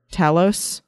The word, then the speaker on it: Talos: In particular, with the scene where they had Ben Mendelsohn's character, Talos, is that how you say it? – Yep.